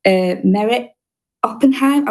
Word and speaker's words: Meret Oppenheim: It's by an artist called Merit Oppenheim. I'm really rubbish at pronouncing names, but I think that's her name. – You do it beautifully.